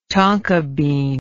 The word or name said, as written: tonka bean